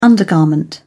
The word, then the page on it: undergarment: British English